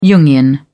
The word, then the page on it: Jungian: British English